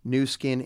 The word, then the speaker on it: Nu Skin: He was a former vice president of New Skin International. And many people probably know that name. It's spelled N-U, S-K-I-N.